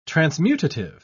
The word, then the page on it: transmutative: British English